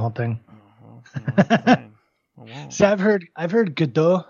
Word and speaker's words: Gadot: I think I heard her say Gadot somewhere, and then everybody's like, no, it's Godot.